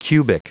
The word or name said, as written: cubic